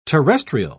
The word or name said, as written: terrestrial